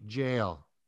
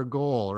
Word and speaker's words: gaol: So, Jean, today we're going to talk about the Governor's House and the Waterloo County Jail. – Mm-hmm. – And just for everyone out there, jail is spelled G-A-O-L. – Right. – And you don't say gaol or goal, right? – Right.